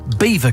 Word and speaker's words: Belvoir: Sam in Peterborough says, lads, Belvoir Castle should actually be pronounced Beaver Castle, which is ridiculous but true. Spelt nothing like it.